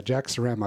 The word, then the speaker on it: Sarama: But yeah, Jack Sarama. I've known you for years, but it's one of those names where you just kind of assume you're saying it right. – Yeah. No, that's perfect.